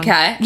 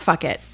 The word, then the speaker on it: Phuket: Phuket. – Yep, that. – Yeah. – Phuket, it's not fuck it.